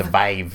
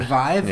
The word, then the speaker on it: Vive: So HTC, how does it pronounce? Vive? – The Vive. – The Vive? – Yeah.